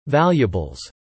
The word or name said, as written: valuables